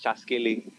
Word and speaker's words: Chaskele: You said it right. It's Chaskele.